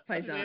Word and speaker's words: Pisani: Hey, that was my husband's name, Pisani. – Yeah, close enough. – My maiden name is Hayes. That was an easy one. So you can pronounce the last name any way you want.